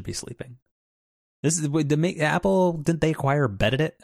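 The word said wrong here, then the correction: Beddit: I think you added an extra syllable in there, but again, Bedit, yeah.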